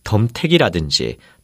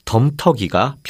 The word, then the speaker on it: 덤터기: 이와 같이 남에게 넘겨 씌우거나 남에게서 넘겨받은 허물이나 걱정거리를 가리켜 말할 때 덤택이라든지 덤탱이 같은 표현으로 쓰는 일이 대단히 많은데 이것은 모두 맞는 표현이 아니고 덤터기가 표준어입니다.